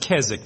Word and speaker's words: Keswick: That word is pronounced Keswick. The W is silent.